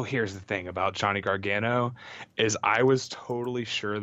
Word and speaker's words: Gargano: Gargano.